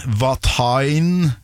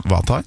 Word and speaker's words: Vatain: en Vatain, sånn det uttales. – Ja. – Vatain, ja.